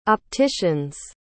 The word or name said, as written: opticians